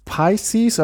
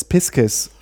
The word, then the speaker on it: Pisces: I can say that it's Piscis if you pronounce it in the Latin way.